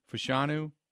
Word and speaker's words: Fashanu: Is that how you pronounce his last name? Fashanu? – Sounds right.